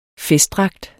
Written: festdragt

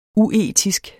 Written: uetisk

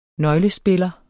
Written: nøglespiller

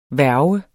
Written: værge

